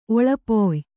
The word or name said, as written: ಒಳ್ಪಯ